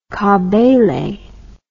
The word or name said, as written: kabele